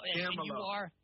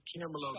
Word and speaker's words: Camelo: Camelo. – That's say it like this. Hold on, do it with me. Ready? Camelo, baby.